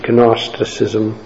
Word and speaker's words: Gnosticism: And so if we understand some of those heresies, one specifically called Gnosticism, which starts with a G, Gnosticism, is how it's spelled,